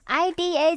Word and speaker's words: Ida: I D A 怎么念啊？Ida，Ida，Ida。